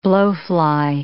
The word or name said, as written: blowfly